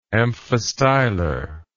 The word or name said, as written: amphistylar